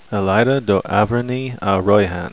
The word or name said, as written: elaida